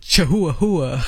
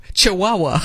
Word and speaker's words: chihuahua: And I was making sure that I didn't do chahua-hua. – Pronunciation is very important. – What is that word? Chahua-hua? – Yeah. – No, chihuahua.